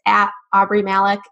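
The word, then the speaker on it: Aubree Malick: So my handle is @AubreyMalek, and my name is spelled AubreE.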